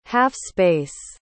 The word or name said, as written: half-space